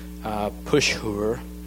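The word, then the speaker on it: Pashhur: After the first seven verses is a prophecy for Pushur. Am I pronouncing that right? Yes? Good.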